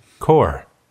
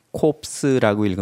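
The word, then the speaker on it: corps: core, core. – 군단. 이거를 할 때는 ps를 읽지 않는다는 걸 꼭 아셔야 돼요. 이거를 core라고 읽어야지 콥스라고 읽으면 안 됩니다. 프랑스 말에서는 자음으로 끝나는 단어들은 자음을 읽지 않아요.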